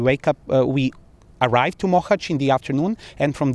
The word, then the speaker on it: Mohács: Now, what are the experiences in Mohács I'm pronouncing it incorrectly. – You're very close. Mohács. – Okay, Mohács. All right, Mohács.